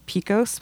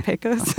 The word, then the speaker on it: Pecos: We played a venue called Trans Pecos, which is very, I'm from Texas, so it's hard for me to say Pecos, but that's how they say it. I would say Pecos.